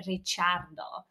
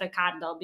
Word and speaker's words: Ricciardo: Actually, if you want to pronounce his name the Italian way, because his parents have Italian roots, it would be Ricciardo. But he likes to call himself Ricardo because that's the way kind of it gets pronounced in Australia, where he's from.